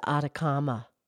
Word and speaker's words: Atacama: That's the Atacama. Atacama. – The Atacama? Are we pronouncing it properly? – I believe so.